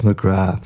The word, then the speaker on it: McGrath: And we'll have to forgive the boss's pronunciation, Mrs. McGrath. Indeed, of course, it's Mrs. McGrath.